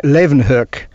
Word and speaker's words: Leeuwenhoek: The way that you should say it is something like Leeuwenhoek.